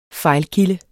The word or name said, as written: fejlkilde